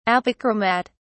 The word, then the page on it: apochromat: British English